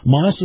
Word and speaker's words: Maus: And remember, Moss is spelled M-A-U-S.